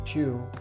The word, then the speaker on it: Chew: Mr. Chow. s- sorry, Mr. Chew.